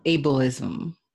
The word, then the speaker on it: ableism: And so what impact, because I've heard this term ableism. Am I pronouncing that correctly? Able? – Yes.